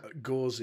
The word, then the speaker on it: Gose: Gose, yeah, that's how you say it, Gose.